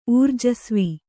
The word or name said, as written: ಊರ್ಜಸ್ವಿ